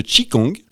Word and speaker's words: Qigong: Alors, je vous présente, vous êtes enseignante de Qigong.